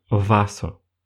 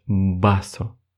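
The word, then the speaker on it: vaso: Например, слово «басо» оно пишется через букву УВ, И изначально мы пытаемся произнести это слово как «васо», но испанцы так не говорят. Испанцы говорят «басо» со звуком «б».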